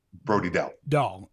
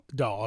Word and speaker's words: Brody Dahl: Do you say her name Brody Ballet? Is that right? – Dow. Brody Dahl. – Dahl. Okay. Okay.